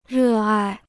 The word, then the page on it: 热爱: rè ài